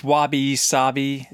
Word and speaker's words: wabi-sabi: One of those movies triggered a thought about the Japanese concept of wabi-sabi, although I think I might have said that wrong. – It's wabi-sabi. – Wabi-sabi. – I believe. I'm not Japanese.